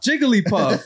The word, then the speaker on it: Jigglypuff: Did I say it right? – No. – How do you say it? – Jigglypuff? – Jigglypuff. – It's a Hillbillypuff. It like, Jigglypuff. Girl. And I was like, oh, damn, well, I got that wrong.